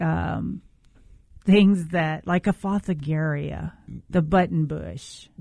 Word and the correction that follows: Fothergilla: Yeah, I always call it Fothergilla. – Oh, yeah, there's, there is an L in there.